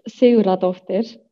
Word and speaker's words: Sigurðardóttir: Ähm, und der Nachname ist Seuradovter.